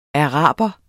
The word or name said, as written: araber